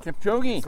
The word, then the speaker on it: Kipchoge: Kipchoge. – Kipchoge.